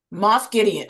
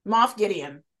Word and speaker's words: Gideon: Moff Gideon. Oh yeah, I didn't say it right. Moff Gideon.